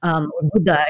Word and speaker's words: Buddha: Like, it's not like I'm suddenly Buddha or something, but Buddha, I should say, because I'm a real Indian and I pronounce it correctly.